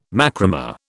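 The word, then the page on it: macramé: British English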